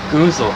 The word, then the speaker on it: goozle: Drizzle, you say? – Goozle. – Goozle. – No, goozle. – How do you spell it? – G-O-O-Z-L-E. – G-O-O-Z-L-E.